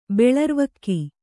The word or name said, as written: ಬೆಳರ್ವಕ್ಕಿ